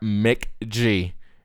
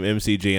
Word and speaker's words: McG: Mick G directed this. – Yeah, and I kept pronouncing his name MCG, and I thought that was a company.